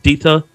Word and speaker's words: Theta: Theta system, I think I'm saying it right, Theta, T-H-E-T-A, differently, like data and data.